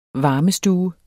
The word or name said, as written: VARMESTUE